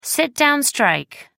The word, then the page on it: sit down strike: American English